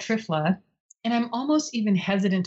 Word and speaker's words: Triphala: But it's an herb called Trifla, that's spelled T-R-I-P-H-A-L-A.